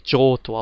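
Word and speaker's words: Johto: Aber es wird tatsächlich Joto ausgesprochen.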